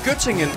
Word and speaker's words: Göttingen: Var det ved Gødringen? – Nej, Göttingen.